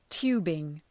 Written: tubing

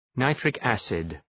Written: nitric acid

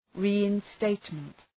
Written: reinstatement

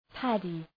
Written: paddy

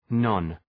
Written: non-

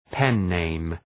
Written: pen-name

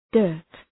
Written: dirt

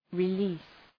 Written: release

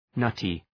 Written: nutty

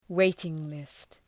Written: waiting-list

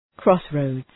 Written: crossroads

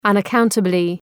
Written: unaccountably

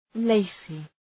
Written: lacy

